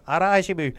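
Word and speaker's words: hara hachi bu: Les Japonais ont un concept, je ne sais pas si je vais bien le prononcer, qui s'appelle le ara-hashibu. OK? Puis, ara-hashibu. – OK, ça c'est mieux.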